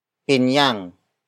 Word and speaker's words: pinyang: Pinyang kasing tamis nang pulot.